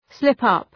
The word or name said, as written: slip up